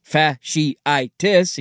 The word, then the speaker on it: fasciitis: Plantar fasciitis, pronounced fasciitis, he did it phonetically, thank you, occurs when the ligament gets irritated and inflamed.